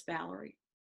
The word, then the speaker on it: Valeria: And actually, it's spelled Valeria, but it's Valerie. I know it's weird. But Valerie Matlock, and I am running for Vice President for Ethics and Standards in Audiology.